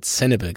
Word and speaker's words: Sanibel: Du siehst, am Ende haben wir uns auf deine Aussprache mit Sanibel geeinigt, Christoph.